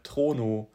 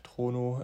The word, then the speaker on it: Trono: Und zwar ist das der Trono. Auch genauso geschrieben, wie ich es ausgesprochen habe. Trono, da haben wir einen Test dazu gemacht.